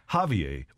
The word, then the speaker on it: Xavier: Because the Google machine writes, in the U.S., Xavier, X-A-V-I-E-R, is pronounced as though it starts with a Z, like Xavier. While traditionally, the name is pronounced as though it begins with an H, more like Javier.